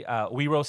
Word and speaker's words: Wero: Wero Seattle. That's Wero like hero with a W. W-E-R-O.